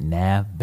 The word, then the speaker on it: Nav: Nav, Bad Habits.